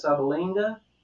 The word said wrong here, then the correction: Subligna: Subligna. – Subligna.